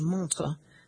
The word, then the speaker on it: montre: Though they were originally sold as Louis Vuitton I and Louis Vuitton II, collectors began referring to them as Monterey, a moniker derived from the American mispronunciation of montre, the French word for watch.